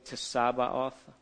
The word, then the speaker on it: Tsabaoth: Yahweh Tsabaoth. Okay, that's because the Hebrew has a letter that's kind of pronounced like a TS.